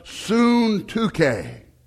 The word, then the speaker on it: Syntyche: So the next time you come across that, it's pronounced Syntyche. Amen. It's pronounced nothing like it's spelt.